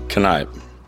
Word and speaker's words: Kneipp: Kneipp. – Can you spell that? – K-N-E-I-P-P.